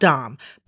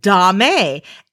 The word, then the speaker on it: domme: It's even changing our pronunciations of well-known words like D-O-M-M-E, which is the female or feminine version of dominant. It is pronounced dom. That's it, just dom. But so many people, all they do, they read it and then they hear it from each other and they mispronounce it dame, and it is caught on. So now so many people believe that this mispronunciation is correct, but it's not. It's just dom. Stop saying dame.